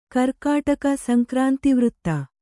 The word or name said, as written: ಗೀರ್ಕಡಗ